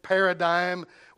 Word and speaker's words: paradigm: The last part of that word is the D-I-G-M. It's one of those words that doesn't sound like it's spelled, paradigm.